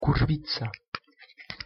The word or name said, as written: kurwica